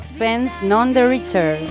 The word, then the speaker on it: Sixpence None the Richer: ¿Lo he dicho bien, hija? – Bastante bien. – Bastante bien. – Sí. – A ver cómo lo dirías tú. Sixpence. A ver, la anglófona. – Sixpence None the Richer.